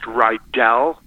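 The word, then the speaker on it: Rydall: My last name is actually pronounced Rydahl, like it's an E. – Oh, that's interesting. So sorry about that. – Yeah. No worries. It's easy to do. It says A-L-L, but it's pronounced like Rydahl High, Bobby Rydahl, you know, just think that.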